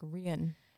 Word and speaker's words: Rian: It's like, it's spelled like Ryan with an I, but I feel like when they say it, it's like Rian, right? – Yes.